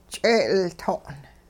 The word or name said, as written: ch'e'ilto'n